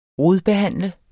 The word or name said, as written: rodbehandle